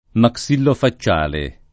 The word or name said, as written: maxillofacciale